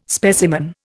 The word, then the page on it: specimen: Indian